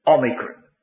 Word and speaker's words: omicron: There's a short O, omicron. O, micron, microscopic. O, little O.